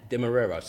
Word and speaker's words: Demerara: Demerara. So it's Demerara. Yeah. – It's like the sugar. You don't go Sainsbury's, you see Demerara sugar.